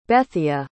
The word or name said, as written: Bethia